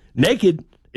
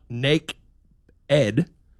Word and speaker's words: naked: Naked is not nake-ed. The E-D is part of the original word itself, which comes from the Old English, nakod. And over time, the pronunciation evolved into naked.